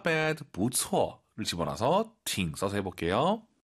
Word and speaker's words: 挺: 초급자분들이 팅 발음 들으면 보통은 듣다만 떠올려요. 挺好的 할때 팅이 듣다가 아니죠. – 아, 不是。 – 네, 성조만 다를 뿐이에요. 병음은 똑같아요. T-I-N-G.